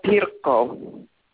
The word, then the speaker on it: Pirkko: I am Pirkko.